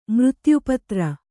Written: ಮೃತ್ಯುಪತ್ರ

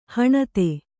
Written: ಹಣತಿ

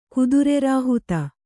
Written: ಕುದುರೆರಾಹುತ

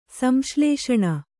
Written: ಸಂಶ್ಲೇಷಣೆ

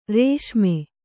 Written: ರಾಸ್ಮೆ

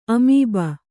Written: ಅಮೀಬ

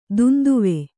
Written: ದುಂದುವೆ

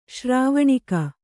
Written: ಶ್ರಾವಣಿಕ

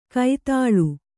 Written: ಕೈದಾಳು

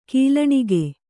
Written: ಗೊಲ್ಲಣಿಗೆ

